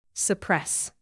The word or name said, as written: suppress